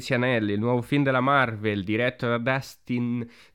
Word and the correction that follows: Destin: Daniel Cretton, ma penso si pronuncia in realtà Destin, tipo destino.